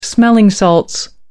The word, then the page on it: smelling salts: American English